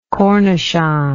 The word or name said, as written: cornichon